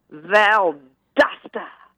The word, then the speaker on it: Valdosta: You're really not saying it correctly. You've got to Valdosta, like that. – Valdosta. – Yeah, you know, give him a little punch.